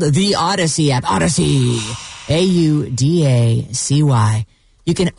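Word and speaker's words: Audacy: Odyssey, A-U-D-A-C-Y. I know it's spelled weird. And make sure to tune in tonight at 9 o'clock on the Odyssey app for a meeting of the Tortured Poets Department.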